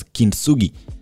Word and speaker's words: kintsugi: So, of course, our keyword goes, or rather is pronounced as kintsugi.